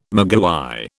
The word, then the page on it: maguey: British English